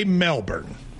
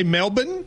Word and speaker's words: Melbourne: So you're saying Americans say Melbourne. – Yes. – But apparently, and I didn't know this, Australians say Melbourne.